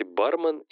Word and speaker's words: бармен: Так, а кстати, бармен или бармен? Так. Бармен. Ударение. Залезняк утверждает, что единственное правильное ударение в слове Бармен. Ударение на первый слог.